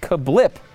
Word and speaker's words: CABLP: Well, he's got a new restaurant out and it's called Cablepa? I have no idea. Kabilpa? It's actually apparently pronounced Kablip, spelled though C-A-B-L-P.